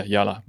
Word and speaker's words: Yalla: Is that how you pronounce it? – Yeah, Yala. – Okay. – So it's exactly the same as like, let's go.